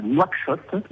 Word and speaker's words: Nouakchott: Alors voilà, aujourd'hui je suis à Nouakchott, donc c'est la capitale de la Mauritanie.